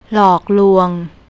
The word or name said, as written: lokloung